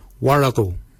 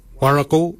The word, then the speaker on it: worragol: Baken is spelled B-A-G-I-N-Y. Baken. Baken is your beard. The upper mouth, we say warago. Warago. Warago is spelled W-O-R-R-A-G-O-L. Warago. Warago is your upper mouth. For your nose, we say moil. Moil is your nose. Moil is spelled M-O-O-L-Y.